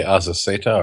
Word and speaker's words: Azaceta: Outcast by Kirkman and Azacieta. How do you say his name? Azacieta? – How do you spell it? – A-Z-A-C-E-T-A. – I'd say Azaceta or Azaceta.